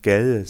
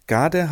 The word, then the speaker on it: Gade: Dänisch ausgesprochen müsste man eigentlich Gell sagen, aber wir lassen es jetzt bei der deutschen Bezeichnung. Gade